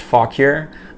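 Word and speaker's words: Fauquier: Here's a new mug that came in with the Falk Here, Virginia, and on the back it's pronounced Falk Here.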